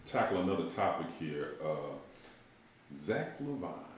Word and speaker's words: LaVine: Zach LaVine.